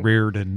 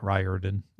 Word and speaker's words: Riordan: It's also Riordan, not Riordan. – You know, I tried. – How do you pronounce this one? L-Y-N-C-H.